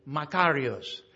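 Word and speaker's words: makarios (μακάριος): Now, the word in Greek is spelled, pronounced in this way, makarios.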